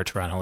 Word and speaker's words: Toronto: I apologize to our Toronto listeners for pronouncing the second T.